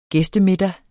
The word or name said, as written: gæstemiddag